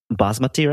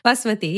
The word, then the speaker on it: basmati: Examples of this are jasmine rice, or I might butcher this pronunciation, basmati rice. – Ah, you actually said it right this time. Yeah. basmati.